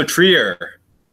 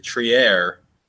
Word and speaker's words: Trier: Um, Alonzo Trier, or er, uh, as Tom learned from Jonathan Macri, Trier, which is what Jake's been saying, and I've been questioning this entire time, but apparently that's how his mom pronounces it.